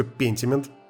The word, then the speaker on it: Pentiment: Но э, все же она читается как, сука, Pentiment, поэтому так и будет которая доступна в Xbox Game Pass для э, Xbox и для ПК, насколько я знаю.